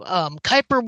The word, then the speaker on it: Kuiper: Moonrange Games is working on a game called Cooper Wanderer. – Kuiper. It's an astrology thing. Don't worry about it. Nobody knows how to say it the first time.